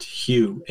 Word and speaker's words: Hu: In the Sufi tradition, that you chant, and the meditation I have there is to chant Hu, H-U, and it's kind of pronounced in between Y-O-U and H-U-E.